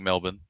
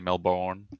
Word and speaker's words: Melbourne: Also, good work on saying Melbourne. Most people, mainly North Americans, overpronounce it. They usually say Melbourne.